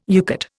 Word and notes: UCATT: Indian

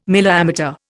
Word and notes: milliammeter: Indian

